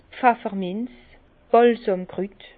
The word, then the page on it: 's Pfefferminz, Bàlsàmkrüt: Bas Rhin; Herrlisheim